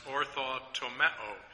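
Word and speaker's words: orthotomeo (ὀρθοτομέω): This phrase in 2 Timothy 2:15 correctly handles the word of truth is based on a Greek word that is pronounced orthotomeo, and literally it means to correct, to cut straight.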